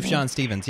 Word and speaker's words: Sufjan Stevens: Yeah, Sufjan Stevens, yeah.